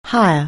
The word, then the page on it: hire: anglais